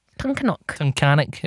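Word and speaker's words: Tunkhannock: Tunkanok. It rhymes with mechanic.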